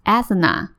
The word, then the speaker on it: Athena: 我决定拼给大家听，是 A T H E N A，Athena Athena。